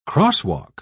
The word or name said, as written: crosswalk